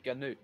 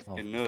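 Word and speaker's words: GNU: Is it not pronounced Ganoot, though? – Yes, Ganoot. – For God's sake, we're not even getting into this debate. It's not worth it. It's Ganoot.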